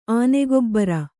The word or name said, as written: ಆನೆಗೊಬ್ಬರ